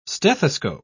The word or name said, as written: stethoscope